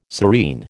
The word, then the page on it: serene: American English